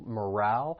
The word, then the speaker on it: morale: Just know that the stress is on that second syllable, morale. And don't be fooled by that E at the end. It's not a long vowel sound, but a short vowel sound, morale.